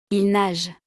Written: il nage

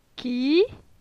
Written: Ci

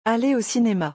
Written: aller au cinema

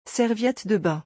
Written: serviette de bain

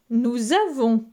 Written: Nous avons

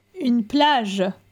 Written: Une plage

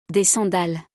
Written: Des sandales

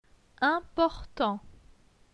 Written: Important

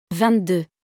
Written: 22

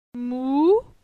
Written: Mou

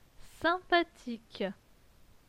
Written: sympathique

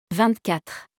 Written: 24

